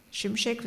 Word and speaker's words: Şimşek: Nur weil die anders aussahen, weil die einen anderen Namen haben, Schimschek, es wird anders ausgesprochen.